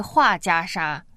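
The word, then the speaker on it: 桦加沙: 那他的名字还挺有趣的，我觉得有点俄罗斯味道啊，华加沙。那万峰老师说这个应该是日本发音，对对对，应该另外一个读音应该是画家沙。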